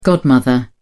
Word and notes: godmother: British English

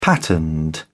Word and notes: patterned: British English

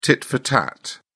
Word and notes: tit for tat: British English

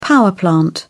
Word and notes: power plant: British English

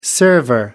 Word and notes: server: American English

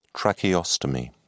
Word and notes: tracheostomy: British English

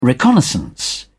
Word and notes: reconnaissance: British English

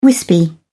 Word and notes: wispy: British English

